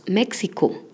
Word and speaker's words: Mexiko: Ich komme aus Mexiko, significa yo vengo de México. Vamos a repetir. Ich komme aus Mexiko. Ich komme aus Mexiko. Habrás notado que México se pronuncia diferente en alemán. México.